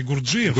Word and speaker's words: Гурджиев: Фамилия у него все-таки Гурджиев. – Гурджиев? Мне почему-то, да. Гурджиев. Слава богу, что вы проверили это. Потому что я мучаюсь уже года 4. – Ударение на «и», и он оставил после себя 6 композиций, угу, которые длятся от 2 минут до 25.